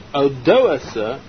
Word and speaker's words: Odoacer: The Vandals had ransacked Rome, and in the year 476 of the Common Era, a king called Odoacer, that's the correct pronunciation of his name, he was a king of the barbarians in Italy.